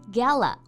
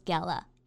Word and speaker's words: gala: 没错啊，春晚呢一般指的是央视春晚，在英文当中呢，我们可以说是 CCTV Spring Festival Gala or CCTV New Year's Gala。 – 对，Gala 指的就是欢快的庆祝活动的意思。那我们呢，注意一下它的发音。在美式英语发音当中呢，它是发 a 的这个音，gala。那同时呢，在英式英语当中也可以叫做 gala，中间发啊的这个音。